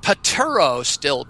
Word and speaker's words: pterostilbene: I will say it the way it's spelled, Pterostilbene, Bean, P-T-E-R-O, Stilbene, S-T-I-L-B-E-N. – Yeah, it's one of those weird things that's spelled P-T-E-R-O.